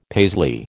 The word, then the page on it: Paisley: American English